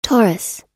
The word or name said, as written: taurus